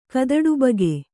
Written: ಕದಡುಬಗೆ

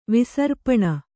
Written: ವಿಸರ್ಪಣ

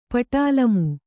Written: ಪಟಾಲಮು

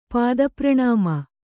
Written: ಪಾದಪ್ರಣಾಮ

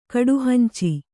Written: ಕಡುಹಂಚಿ

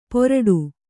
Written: ಪೊರಡು

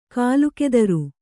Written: ಕೀಲುಕುದುರೆ

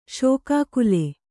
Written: ಶೋಕಾಕುಲ

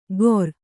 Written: ಗೊರ್

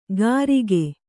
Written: ಕರಿಕೆ

